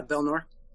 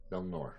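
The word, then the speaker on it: Bel-Nor: So we're heading over to Belnor, right? – Yep. – Am I saying that right? – Yeah, Belnor. – Belnor.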